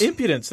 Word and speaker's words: impudence: Mine says a word I probably put the wrong emphasis on the wrong syllable, impudence. – Impudence. That's fine.